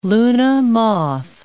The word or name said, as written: luna moth